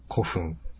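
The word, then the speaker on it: 孤憤: 憤ると書いて。古墳。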